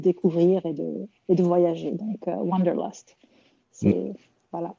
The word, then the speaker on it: Wanderlust: Alors, comment on prononce en anglais Wanderlust euh, Wanderlust, c'est ça.